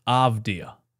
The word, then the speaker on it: Avdija: I can say his name right. His name is Avdija.